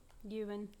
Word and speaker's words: Ewan: I think that's how you say his name. Ewan? – Ewan. – Ewan? – Yes.